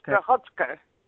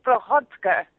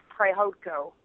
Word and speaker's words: Prychodko: It's actually, it's of Ukrainian descent, so it's pronounced Prichotko. – Okay, say again? – It's Prichotko. But in layman's terms, or as I like to call it, the American way, it's Prehodko or Prehodko.